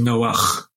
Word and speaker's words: Noach: It's Noah, the way you pronounce it in English, and the Noah of the Ark in Hebrew is Noach. That's a different sound there.